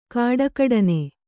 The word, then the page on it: ಖಡಖಡನೆ: khaḍakhaḍane